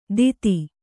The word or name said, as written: ದಿತಿ